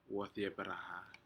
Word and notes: wodyperaha: Aneityum

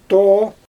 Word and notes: too: Xyzyl

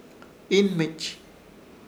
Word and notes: Ihnmich: Ishir; Chamacoco

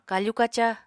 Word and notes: kafu-kaca: apatani

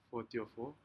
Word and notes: fotyofo: Aneityum

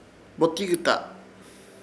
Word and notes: Botikyta: Ishir Chamacoco

